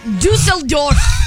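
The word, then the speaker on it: Düsseldorf: They are on tour doing a 10-show residency out in, I'm not sure how to pronounce this, but I'm going to try. – Okay, go. – In Dusseldorf. Germany. – Okay. Okay. Where, you got it right.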